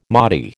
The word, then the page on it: Mahdi: American English